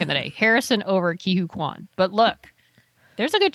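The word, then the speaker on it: Ke Huy Quan: Of course, I really want Ki-Hoo Kwan. Is that how you say it though? Ki-Hoo Kwan? – It is.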